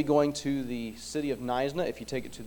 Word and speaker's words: Knysna: it starts with a K, silent K, like knife, Knysna.